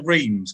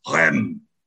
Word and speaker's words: Reims: But the actual Basilica of Saint Denis, if we're going down that route, pronunciation, I hate it when people go to Reims, because they go, you go, where have you been? To France. And they go, Rennes.